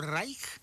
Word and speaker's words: Reich: Sí, más nada más para la gente como usted lo pronunció correctamente en alemán, dijo Reich.